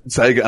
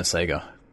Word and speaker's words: Sega: It's weird how the internet's kind of destroyed the Australian pronunciation of Sega. Because it actually says Sega in like all of the ads and shit we had as a kid.